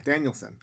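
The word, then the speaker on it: Danielsen: And it stars a man who starred in his first film, Reprise, Anders Dennison Lai, or Danielson. Yeah, Danielson. I said it right.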